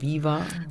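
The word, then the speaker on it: Veeva: Fief, VEEV. – Fief. – Oder wird das aber ausgesprochen? Viva? – Viva Viva.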